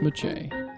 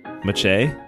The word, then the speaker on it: Maciej: Ooh, actually, I knew somebody with that name, and it's pronounced Maciej. – Maciej? – Yep.